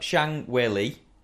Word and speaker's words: Zhang Weili: Right, so I've got Zhang Weili, is that right? – Okay, yeah. – And the other one looks like Joanna Jedrzejczyk. Definitely Polish. – Nearly. So we've got Zhang Weili and we've got Joanna Jędrzejczyk.